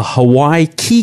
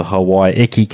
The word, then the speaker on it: Hawaiki: I called it the Hawaii Key cable, but I heard it pronounced while I was on their ship last week, or the ship that they've contracted to build a lot of the cable, and it was referred to as the Hawaii Eki cable. So I stand corrected, and sorry for anyone who's picked up my incorrect pronunciation there. So yeah, I got to tour the responder, which is laying the undersea cable.